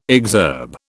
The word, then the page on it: exurb: British English